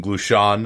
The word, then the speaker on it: Glushon: Woj just recently wrote a piece saying that Danny Ainge and Horford's agent, Jason Glushon, I think it's Glushon is how you pronounce it,